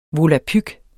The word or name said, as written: volapyk